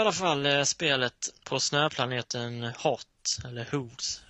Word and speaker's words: Hoth: Hur säger man egentligen? – Jag alltid sagt, men Hot säger de i filmerna väl? – Ja, kanske de gör. – Det borde uttalas Hoth. – Men ja, de säger ju inte, de säger hot. – Gör det enkelt så säger vi hot.